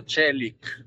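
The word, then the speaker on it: Çelik: Si pronuncia Celic. – Celic. – No, Celic. Celic, che significa acciaio.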